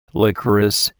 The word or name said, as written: licorice